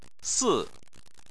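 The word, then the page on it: si4: tone 4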